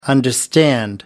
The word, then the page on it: understand: American English